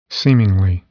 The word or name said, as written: seemingly